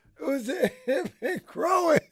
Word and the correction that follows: groin: No, you're groin. Why are you saying it? There's no G on the end of it.